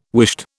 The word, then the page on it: whisht: British English